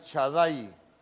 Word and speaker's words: Shaddai: Cuando la Biblia habla de que, de que Dios es el todopoderoso, esa palabra todopoderoso es una palabra que se escribe Shaddai, chadai Y el chadai es,